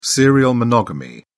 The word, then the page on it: serial monogamy: British English